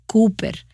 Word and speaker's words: Cowper: Ahora, el nombre se pronuncia Cowper, pero no lo sabrías con solo mirar cómo se escribe.